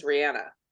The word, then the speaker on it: Rihanna: Although I recently found out the actual correct pronunciation is Rihanna.